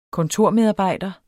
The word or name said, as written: kontormedarbejder